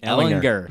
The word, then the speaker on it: Ehlinger: Pardon me, Sam Ehlinger with a hard G at this point, right?